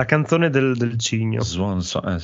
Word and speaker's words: Swansong: Beh, Swan Song.